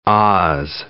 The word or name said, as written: oz